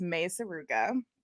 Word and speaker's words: Mei Suruga: Also, you guys did say it right. It is Mei Suruga.